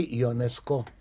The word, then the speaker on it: Ионеско: Эжен Ионеско, ныне, увы, покойный. Большую часть жизни Ионеско провел во Франции, отсюда ударение в его фамилии на последнем слоге, и в 1970 году был избран членом Французской академии.